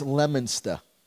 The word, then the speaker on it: Leominster: It's Leominster is how it's said. – Leominster? – Leominster. That's how we say Leominster? – It.